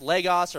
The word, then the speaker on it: logos: So it's L-O-G-S, but it's pronounced logos or logos.